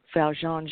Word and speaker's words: Valjeanne: By the way, if you're listening live tonight, on this is Monday, May the 18th, and you want to talk to Valjeanne Jeffers.